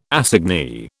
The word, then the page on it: assignee: British English